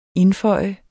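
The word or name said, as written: INDFØJ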